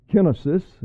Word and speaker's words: kenosis: And from this we discern the principle of kenosis, is the Greek pronunciation. Kenosis is how we say it.